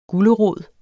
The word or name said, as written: gulerod